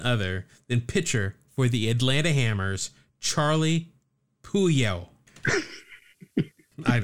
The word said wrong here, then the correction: Puleo: Is that how you say his last name? Puleo? – Yeah, that's it.